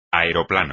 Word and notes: aeroplano: espagnol

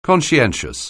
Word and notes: conscientious: anglais

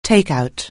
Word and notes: take out: anglais

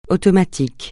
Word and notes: automatique: francais

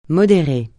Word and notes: modérer: francais

